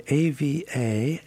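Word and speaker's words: Éabha: And Ava goes by Ava, even though her name is in the Irish is spelled E-A-B-H-A, Ava, pronounced Ava McMahon.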